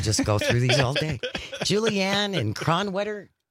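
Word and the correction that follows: Kronenwetter: Cronenwetter. – That doesn't feel like a place. – Cronenwetter. – Spell that for me. – K-R. – Oops. K-R. – O-N-E-N-W-E-T-T-E-R.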